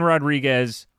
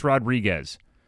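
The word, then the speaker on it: Rodrigues: There is Olivier Rodrigue. That is a player, and that is spelled as Rodrigue. There's no S. But Evan Rodriguez is pronounced that way by the...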